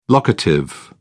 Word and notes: locative: British English

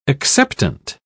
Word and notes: acceptant: British English

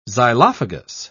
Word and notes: xylophagous: British English

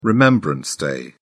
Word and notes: remembrance day: British English

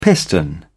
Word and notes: piston: British English

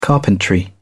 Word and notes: carpentry: British English

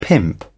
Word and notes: pimp: British English

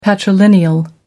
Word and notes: patrilineal: American English

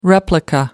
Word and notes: replica: American English

